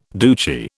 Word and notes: Duce: British English

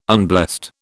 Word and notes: unblessed: British English

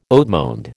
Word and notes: haut monde: American English

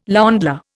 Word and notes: ländler: Indian

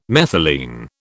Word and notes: methylene: British English

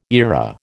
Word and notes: eyra: American English

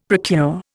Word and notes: procure: Indian

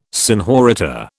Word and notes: senhorita: British English

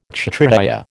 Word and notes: Kshatriya: American English

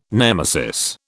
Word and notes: nemesis: British English

